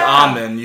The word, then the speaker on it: almond: But it's actually almond. You're not supposed to say the L. It's actually silent.